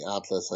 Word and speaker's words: Atlus: Oh, Al- Al- Altus, I should say. Um, can't even pronounce the creators right. But it, is this a huge announcement, or am I overplaying it there? – Firstly, it is actually Atlus.